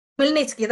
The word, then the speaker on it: Khmelnytsky: Is there some comparison between, say, the rebellion of this man whose name I find hard to pronounce, Kaminski? – Khmelnytsky, that's yeah. – Khmelnytsky, yeah.